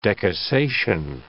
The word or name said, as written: decussation